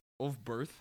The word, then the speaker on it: Ulfberth: U-L-F-B-E-R-T-H, Ulfberth, and space, Bark, B-A-R-K, Bark.